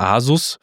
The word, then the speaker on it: Asus: Einerseits geht es um das Tool MyAsus, also von dem Anbieter Asus, vielleicht deutsch ausgesprochen.